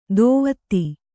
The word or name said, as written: ಧೋವತಿ